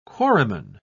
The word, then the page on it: quarryman: British English